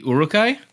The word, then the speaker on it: Uruk-hai: The Uruk-hai? – Yes, them.